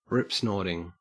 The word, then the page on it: ripsnorting: Australian English